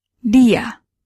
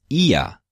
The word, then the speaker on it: dia: Dia. Dia. – Dia, he or she. Great. It sounds a little bit like hia or shia. Dia. Sometimes you'll hear it pronounced as ia instead of dia.